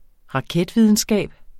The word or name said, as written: raketvidenskab